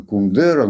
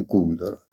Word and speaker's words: Кундера: Мы все привыкли произносить его с ударением на втором слоге Кундера, но правильно все-таки Кундера.